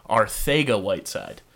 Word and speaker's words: Arcega-Whiteside: So, for everybody at home, J.J. is from Spain, is where his family's from, and it's actually pronounced J.J. Arcega-Whiteside.